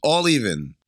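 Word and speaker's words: A11Even: How do you think that you're supposed to pronounce Kyrie's name on Twitter? A11 even? Is it all 11? Is it pronounced 11 but spelled differently? This is more cryptic than Elon Musk's baby. – I'm going to say all 11. All even. All even. – No. – It's all even.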